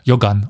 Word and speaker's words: Johann: Renner, der im Russischen schön als Jogan ausgesprochen wird. Weil kein H, sondern G und so.